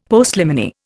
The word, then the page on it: Postliminy: Indian